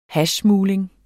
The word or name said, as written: hashsmugling